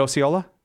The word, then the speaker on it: Osceola: Is it Osceola? – Yes.